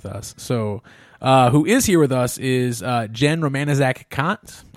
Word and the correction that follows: Jenn Romaniszak Kant: I said it wrong. It's Jenn Romaniszak Kant, not like the philosopher.